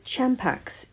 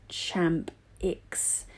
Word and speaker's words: Champix: So Champax is pronounced Champix.